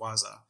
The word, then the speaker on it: waza: And what you see at the top is the expression or the vocabulary word in Kiswahili is pronounced waza.